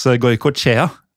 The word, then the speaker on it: Goikoetxea: Jeg tror det uttales Goikoetska. – Ja, til dere lyttere, det skriver, altså etternavnet skrives G-O-I-K-O-E og så T-X-E-A.